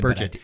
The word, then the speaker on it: Burchett: Burchett, his last name begins with a B, but it's pronounced Burchett.